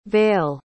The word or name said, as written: vaile